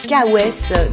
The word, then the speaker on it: K.O.S: Donc c'est K.O.S, donc là je l'ai bien dit, je l'ai bien prononcé.